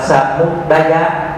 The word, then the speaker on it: Samudaya: Thì nên đọc là Samudaya.